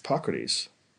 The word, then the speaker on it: Hippocrates: How do you say that? – Hippocrates. – Right. Like hypocrite. No.